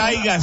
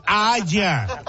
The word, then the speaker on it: haya: No se dice aiga, se dice haya.